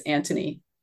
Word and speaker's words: Antony: So Anita is the Anthony, the Mark Anthony counterpart. – I'm gonna stop you because you're saying it wrong. It's Antony. – Anthony, very sorry, you're right. There is no H.